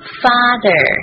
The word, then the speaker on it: father: Happy Father TH 的发音 V 声带震动，咬舌 V Father，最后是 R 一个卷舌 Father。别忘了 A 的发音是 A 长音啊，要饱满。